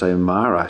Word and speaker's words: O'Meara: Her name's spelled O'Meara, but it's actually pronounced O'Mara.